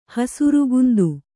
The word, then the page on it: ಹಸುರುಗುಂದು: kannada